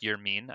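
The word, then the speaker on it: Yermín: Um, he has kind of been forgotten about because the story so far for the White Sox this season has been Yerman, the Vermin, Mercedes, it's actually pronounced "you're mean.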